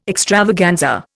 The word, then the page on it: extravaganza: Indian